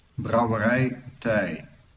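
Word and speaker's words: Brouwerij 't IJ: Brouwerij Tij.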